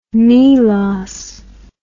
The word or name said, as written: milos